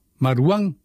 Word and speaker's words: Marwan: Y Marwan, no, Marwan, a ver, ¿en qué quedamos que se tenía que pronunciar su nombre? – Creo que era Marwan. – Marwan. Bueno, Marwan, sí.